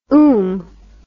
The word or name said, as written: oom